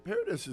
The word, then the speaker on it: Paradis: Did I say that right, Paradis? – Yeah. Paradis is good now.